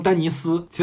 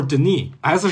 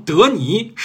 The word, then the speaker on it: Denis: 首先要跟大家说一下啊，丹尼斯· 维伦纽瓦的这个翻译其实是有问题的，因为维伦纽瓦是加拿大魁北克人，他是一个法语区，如果他的名字按照法语的读法的话，不应该读成丹尼斯，其实就是 Denis，S 是不发音的，所以可能叫成德尼是更合理的一个翻译，但无所谓了啊，毕竟目前这还是中国大陆的一个官方翻译，所以我们就还是称他为。